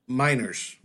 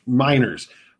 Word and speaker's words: Meinerz: How do you pronounce his last name? – Meinerz. – Meinerz from Wisconsin Whitewater, a Division three prospect.